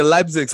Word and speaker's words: Leipzig: So, Leipzig.